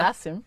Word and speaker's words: Nassim: Nassim? – Yeah, Nassim.